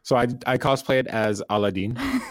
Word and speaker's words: Aladdin: Or Aladdin, as the American English pronounces it.